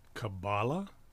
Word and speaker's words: Kabbalah: Kabbalah, C-A-B-A-L-A? – K-A-B-B-A-L-A-H. – Wow. – Kabbalah. – K-A-B-B-A-L-A-H.